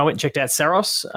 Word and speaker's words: Saros: I've got to correct myself. I've heard too many people mispronounce it over the years. And Greg Loudon in the little presentation that I got, keep saying Saros. I need to say Saros.